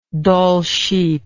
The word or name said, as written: Dall's sheep